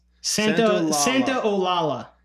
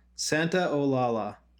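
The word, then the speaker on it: Santaolalla: I see it's Gustavo Santolala. – Santolala. – I have a A after the L's. – Santa Olala. – Santa Olala. All right. – Yeah. Okay. Gustavo Santa Olala.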